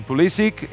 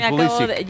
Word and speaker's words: Pulisic: Pues porque Pulisic, él ha pedido en entrevistas, en el anterior Mundial, recuerdo, nos tocó cubrir Estados Unidos, y él, como tiene origen polaco, es la correcta pronunciación.